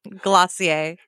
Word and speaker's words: Glossier: And now help me pronounce this company's name. – Glossier. – Glossier. – Yeah, it's a little bit fake French.